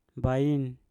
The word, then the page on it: bain: mpur